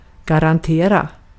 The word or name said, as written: garantera